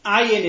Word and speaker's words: Ayin: Another difference, the letters Gimel and Ayin among Ashkenazim will be pronounced like I just did. Gimel is G, Ayin is A. Whereas in Yemenites and some Sephardim, they pronounce them as guttural sounds out of the throat. So it's ga. And the ayin is ga.